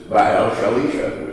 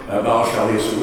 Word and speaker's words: Baal Shalisha: It is about the prophet Elisha and this guy who is Baal Shalisha, which is exactly how you pronounced it nicely, Tom. Baal Shalisha was just the name of the town that he was from.